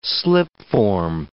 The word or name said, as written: slipform